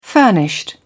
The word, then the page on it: furnished: British English